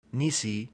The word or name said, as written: nisi